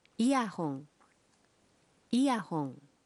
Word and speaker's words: イヤホン: Earphones are pronounced as イヤホン、イヤホン。